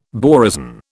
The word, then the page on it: borazon: British English